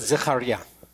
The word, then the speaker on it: Zechariah: How do you say Echelrim Zechariah? – Zechariah. – Zechariah. – Yeah. – Was that close? – Zechariah. Very close.